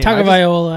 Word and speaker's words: Tagovailoa: Tagovailoa. I would say Tagovailoa, personally. – Tagovailoa.